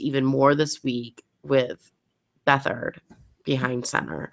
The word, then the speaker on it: Beathard: It is still unknown how the chemistry is going to be between Garçon and their new starting quarterback for the 49ers, CJ Beathard. How do you pronounce his last name? Brandon's muted. – Oh, sorry, I was typing. Muted. Beathard.